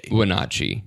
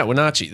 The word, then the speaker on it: Wenatchee: I think it's a soft A. – Wenatchee. – Yeah, Wenatchee.